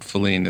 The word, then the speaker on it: Filene: So, and what we've heard from the family members of Edward Feline is that Feline is the proper way to pronounce it.